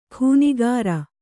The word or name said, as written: ಖೂನಿಗಾರ